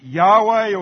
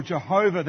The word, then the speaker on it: Yahweh: We pronounce it Yahweh or Jehovah.